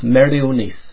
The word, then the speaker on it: Meirionnydd: In Mary's day, this was known as the county of Meirionnydd, but is now part of Gwynedd. A double D in Welsh is pronounced like the TH in the word breathe.